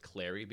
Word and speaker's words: Cleary: I'm going to go with Clary because it rhymes with the word fairy.